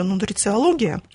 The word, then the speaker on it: нутрициология: У нас сейчас самая модная тема — это нутрициология. Правильно сказала слово? – Нутрициологи, да.